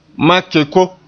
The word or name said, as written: ma-keko